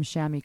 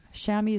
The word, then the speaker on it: chamois: Chamois is spelled C H A M O I S. Uh, that's a French word, and um, it's also I've seen it spelled chamois, S H A M M Y.